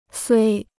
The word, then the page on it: 虽: suī